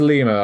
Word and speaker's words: Lima: Is it Lima? – It is Lima.